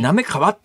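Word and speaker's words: 行川: これあの、なんで関東の人読めるかというと、滑川アイランドとかですね、千葉県になめ川っていうところがあるんで、はいはい、この行をなめと読む、読める人は、関東にはそこそこ多いと思うんですが、うん、まあ関西は読めないだろうな。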